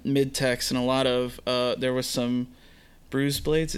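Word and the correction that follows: Brous: Browse blades.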